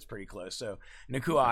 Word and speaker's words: Nacua: It's basically Nacua. So Nacua? Not quite so French there.